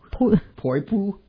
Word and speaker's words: Poipu: The number one beach this year is Poipo Beach in Hawaii. Okay, pronounce this one. P-O-I-P-U. Poipo? Poipu? – Poipu. – Poipu. Poipu Beach in Hawaii.